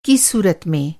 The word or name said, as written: सूरत